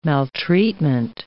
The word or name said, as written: maltreatment